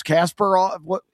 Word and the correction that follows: Kaprizov: Kaprizov. – Kaprizov. I put the S before the P.